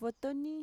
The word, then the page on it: votoni: Mixtec